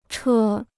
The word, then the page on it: 车: chē